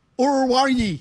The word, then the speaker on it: Oruwariye: Amani Orurari. Amani Orurari. – You sound like a dog. – You pronounce this. O-R-U-W-A-R-I-Y-E. – Uruwari. – Uruwari.